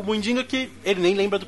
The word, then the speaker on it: Muidinga: É Muidinga.